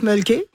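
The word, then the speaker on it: Mölkky: Alors, ça s'appelle le Mulky. On prononce Mulky. – Ouais. – Et c'est M-O-L-K-K-Y.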